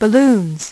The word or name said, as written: balloons